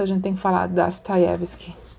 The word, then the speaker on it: Dostoiévski: Ah, uma coisa que eu, eu, tinha dúvidas era com relação à pronúncia né, do, do nome Dostoiévski, e agora eu sei que quem estudou Dostoiévski não fala assim, fala Dostoiévski, porque os Os eles são átonos, eles não têm um acento de intensidade.